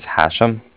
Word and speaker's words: Hashem: My name is Hashem. That's H-A-S-H-E-M, as in mother at the end.